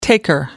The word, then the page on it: taker: American English